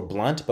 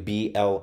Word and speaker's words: Blount: That is Terrence, Terrance underscore Blunt, but with an O. So I'll spell it for you, Blount.